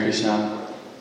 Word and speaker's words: Krishna: particularly with the short A on the end of Krishna, not the long A, which is a long A with Krishna is indicating Draupadi.